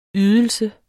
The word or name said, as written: ydelse